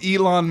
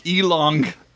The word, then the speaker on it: Elon: Yeah, but Jim also called Elon Musk Elong.